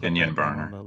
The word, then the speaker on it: Kenyon Barner: So we put Kenyon Barner.